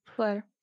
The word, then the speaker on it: Flare: Čte se to Flair? – Flair.